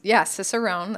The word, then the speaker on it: Cicerone: Yep, here's your Cicerone, that is how it's pronounced, certification program.